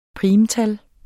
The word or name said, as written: primtal